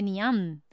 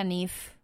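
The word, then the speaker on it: Niamh: esto se escribe Niam, que hay gente que lo dice Niam, se pronuncia Nif, ¿vale?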